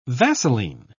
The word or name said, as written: vaseline